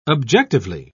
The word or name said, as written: objectively